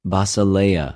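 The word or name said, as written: βασιλεια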